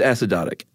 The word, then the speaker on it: acidotic: It's acidotic. – Oh, it is. – Yeah, I actually probably would have made it a long O. – Yeah, no long O apparently.